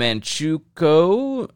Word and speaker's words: Manchukuo: The Liaoning province became part of the Japanese-controlled puppet state, and Manchukuo.